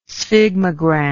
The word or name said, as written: sphygmogram